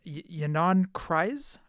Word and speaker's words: Ynon Kreiz: The talks on a possible sale come a few months after the California based company's chief executive officer, and I can never pronounce his name, Ynon Kreiz, I think his name is. – Close enough.